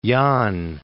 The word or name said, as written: Yonne